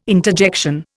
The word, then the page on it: interjection: Indian